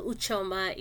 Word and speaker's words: Ucheoma: My name also, Uchoma, has an Udamkpi, so U-C-H-E-O-M-A. So the C-H there is an Udamkpi.